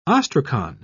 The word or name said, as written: ostracon